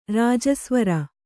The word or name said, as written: ರಜಸ್ವರ